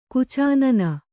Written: ಗಚ್ಚೆನ್ನೆ

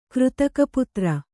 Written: ಕೃತಕಪುತ್ರ

